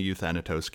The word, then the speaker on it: Euthanatos: Yeah, I'm in the Euthanatos camp. Generally in Greek, the third from last, the antepenultimate syllable gets emphasis, but it's a made up word.